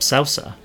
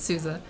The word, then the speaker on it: Sousa: Because I know names sometimes. Okay. Not even who they are, but it's Sousa.